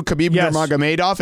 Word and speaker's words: Khabib Nurmagomedov: Were you impressed that I knew Khabib Nurmagomedov and said his name properly, along with Islam Makhachev? – Yes, the Islam Makhachev was very impressive.